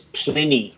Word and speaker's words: Plini: Pliny. Is that how you say it? – I think it's Pliny. – Oh, Pliny. – Pliny. P-L-I-N-I.